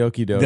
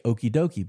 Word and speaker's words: okie-doke: If you don't know about the okey-doke, it's spelled O-K-I-E-D-O-K-E. Some people would read that and say okey-dokey, but no, that would just show you're very white.